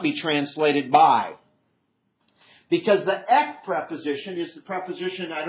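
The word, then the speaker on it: ek (ἐκ): Even if you can't read Greek, you probably can pronounce that as ek because it looks like an E and a K, doesn't it?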